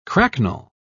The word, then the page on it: cracknel: British English